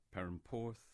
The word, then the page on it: Perranporth: English